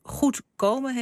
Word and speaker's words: Goed komen: Goed komen heet het, met de klemtoon dus op dat komen.